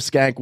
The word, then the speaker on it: Schenck: It's spelled S-C-H-E-N-C-K. – Oh, my God. – Pronounced Schenck.